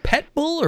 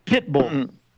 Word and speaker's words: pit bull: Now, is it pet bull or pit bull? – Pit bull. – I always hear people with the pet bull. – They're not pronouncing it, or they're not enunciating properly.